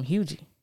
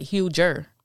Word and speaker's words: Huger: He was from Hughie. – Oh, I didn't even know that was a real place until a couple. And I was saying it wrong because it looks like Huger.